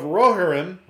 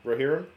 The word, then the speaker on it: Rohirrim: Turns out the Lord of the Rings: The War of Rohirrim. Did I say that right? – No. – Rohirrim.